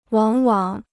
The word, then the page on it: 往往: wǎng wǎng